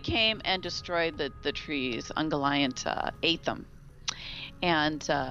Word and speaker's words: Ungoliant: I'm sorry. It's Ungoliant. – Ungoliant, right. – Not Ungoliant. I always pronounce that wrong. It's Ungoliant.